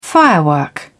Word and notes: firework: British English

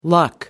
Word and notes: luck: American English

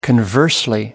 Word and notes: conversely: British English

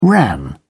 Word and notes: ran: British English

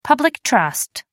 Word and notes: public trust: American English

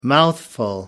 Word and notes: mouthful: American English

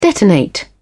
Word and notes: detonate: British English

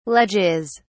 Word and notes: ledges: British English

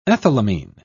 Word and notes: ethylamine: British English

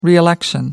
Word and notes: reelect: American English

